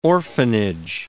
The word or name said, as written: orphanage